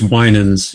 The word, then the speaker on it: Winans: And am I pronouncing that last name right? – I think it's Winans.